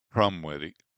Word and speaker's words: Krumwiede: Judith Crumweedy, and that's spelled K-R-U-M-W-I-E-D-E, Urbana.